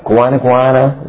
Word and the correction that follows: Guayaguayare: is that right? I'm trying to remember how to pronounce some of the names. – I don't know. Guayaguayare. It's hard to pronounce. Guayaguayare, yes. It's further south than Mayaro that I spoke about.